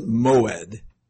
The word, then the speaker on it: moed: And the word there is moed, M-O-A-D. I don't know how they pronounced it, but we pronounce it moed is how it's pronounced.